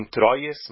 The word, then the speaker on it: Troyes: Se escreve T-R-O-Y-E-S. Por isso muitos leem Troyes, mas não é assim que se fala em francês. Um colega francês uma vez me falou, me corrigiu. É Troyes, que se chama a cidade onde o Rashi nasceu.